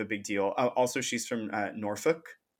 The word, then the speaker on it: Norfolk: That was another like not super challenging one, but I just wanted to say it correctly because you know, with us, we would say like Norfolk. – Yeah, yeah, yeah. It's like the pronunciation of the L.